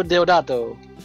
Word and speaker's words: Deodato: Ruggiero Deodato.